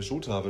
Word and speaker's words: Jota: Diego äh, Jota wird er ja ausgesprochen. Diego ne? Jota, ne? ja.